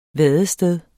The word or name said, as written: vadested